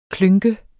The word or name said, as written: klynk